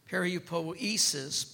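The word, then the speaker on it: Peripoiesis (περιποίησις): Now, that word, possession, this is the Greek word, is periopoiesis. Peripoiesis, P-E-R-I-O-P-O-I-E, accent on the E-S-I-S.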